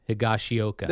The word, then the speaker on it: Higashioka: Can you pronounce his last name? – I can. Watch this. Kyle Higashioka. – There you go.